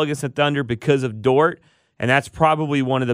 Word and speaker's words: Dort: And they've got my boy named Dort, I believe is how I pronounce it. – Lou Dort, yes.